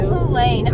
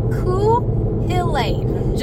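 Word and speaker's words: Cú Chulainn: I want to call him Cthulhu. – Cthulain. I know, that's why I looked up how to say the name, because I was like, I don't get it. It's Cthulain. Just try that.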